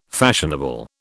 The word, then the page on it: fashionable: British English